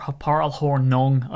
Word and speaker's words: Paul Hornung: So I have to choose between Amon Green and Paul Horning. Or Paul Hornung, I should probably pronounce it correctly instead of Horning, but it's just my Irish accent.